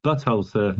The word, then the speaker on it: Butthole Surfers: I think there was a mispronunciation of the word of the name there. – Oh, sorry, Butthole Surfers. – Butthole Surfers. – That's it. Yeah, not Buttonhole. Butthole Surfers.